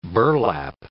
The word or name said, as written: burlap